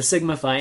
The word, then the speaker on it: Sigma Phi: I always pronounce it Sigma Phi. – Okay, so Sigma Phi. – And sort of like Semper Phi, only it's Sigma Phi.